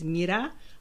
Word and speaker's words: Mira: But pronunciation of my name is Mira, like miracle.